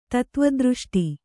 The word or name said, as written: ತತ್ವದೃಷ್ಟಿ